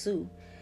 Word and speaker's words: Sioux: I think it's pronounced Sioux. S-I-O-U-X Nation, okay?